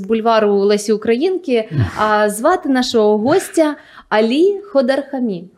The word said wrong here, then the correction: Ходарахмі: Ходарахмі, якщо правильний наголос.